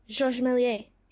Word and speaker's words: Georges Méliès: George Romilier or whatever? – Georges Méliès.